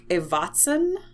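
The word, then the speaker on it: Ewazen: So the first of those is the oboe, and this composer, Eric Evatzen, I believe is how you say it, it's E-W-A-Z-E-N, wrote a piece in 1999 called Down a River of Time.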